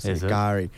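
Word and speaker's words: K'gari: It's kind of like a half silent K. Gari.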